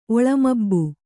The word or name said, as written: ಒಳಮಬ್ಬು